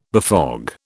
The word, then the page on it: befog: British English